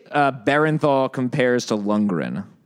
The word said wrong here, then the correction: Bernthal: Bernthal? – Bernthal?